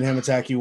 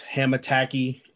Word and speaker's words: Hamattacky: It's your boy, Evan Hamataki, once again. Follow me on Twitter, at Hamataki, H-A-M-A-T-T-A-C-K-Y. It's a lot of words. It's difficult, but hey, you'll pronounce my last name right, so.